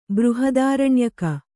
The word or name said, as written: ಬೃಹದಾರಣ್ಯಕ